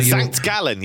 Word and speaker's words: St. Gallen: But, yes, Zurich against St. Gallen. Have I pronounced that correct? – You know, St. Gallen, yes.